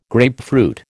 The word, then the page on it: grapefruit: American English